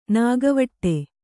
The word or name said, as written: ನಾಗವಟ್ಟೆ